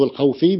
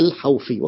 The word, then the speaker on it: الحوفي: والجار والمجرور والعاطف والمعطوف، وأكثر الناس استقصاء لذلك الحوفي، تلفظ الحوفي بسكون الواو وفتح الحاء، ليست الحوفي كما يذكرها البعض، هي الحوفي، وهو عالم جليل ويعني يذكر كثيرا جدا في كتب الإعراب.